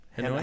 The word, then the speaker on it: Hanoi: On a trip to Hanoi. I don't know how you pronounce it. In Vietnam? – Hanoi? Hanoi, yeah. – Hanoi, yeah. – Hanoi, yeah. In Vietnam.